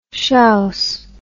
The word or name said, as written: shouse